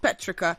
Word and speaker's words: petrichor: Petrica. – Petrica. – Yes. – Ah, Petrica. – Cute. – It is P-E-T-R-I-C-H-O-R, but it's pronounced Petrica.